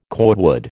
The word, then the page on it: cordwood: American English